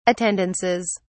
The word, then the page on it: attendances: British English